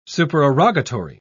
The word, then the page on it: supererogatory: British English